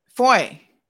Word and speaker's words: Fahy: is it pronounced Fahy? Is that how he pronounces it? – Foy. Andrew Foy. – Foy. – Yes.